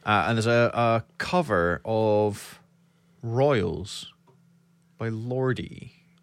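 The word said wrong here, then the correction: Lorde: Or Lord. – Lord. – She pronounces her name.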